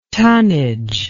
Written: tunnage

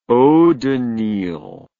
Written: eau de Nil

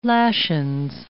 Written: lashins

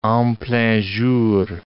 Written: en plein jour

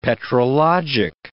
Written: petrologic

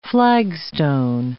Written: flagstone

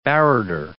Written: barrater

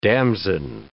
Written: damson

